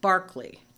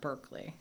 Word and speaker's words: Berkeley: They misspelled General Moses Cleveland's name, C-L-E-A-V-E-L-A-N-D, on the city charter, and that's why it is Cleveland. – And the philosopher who has the E in his name is pronounced Barkley, not Berkeley.